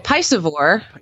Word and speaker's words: piscivore: Like, I think if you're going to be a pescivore, then that's all you eat, right? – And, well, a piscivore. – Yeah. Piscivore. – If you're piscivorous, pescivore. – I've been saying it wrong all these years.